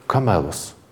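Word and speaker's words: камелос (κάμηλος): По-гречески слово «верблюд» произносится как «камелос».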